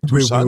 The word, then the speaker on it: Tucson: Tucson qui s'écrit Tucson, là, mais Tucson.